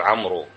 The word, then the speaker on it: عمرو: هاي الواو فقط لتكون فارقة بين عمرو وعمر، واضح؟ يقال نستعارها من داود، داود فيها واوين، تنطق بواوين وتكتب واو واحدة، وين الثانية؟ أعطاها عمر رحمة به حتى يتميز بها عن عمر، لا يقال عمرو.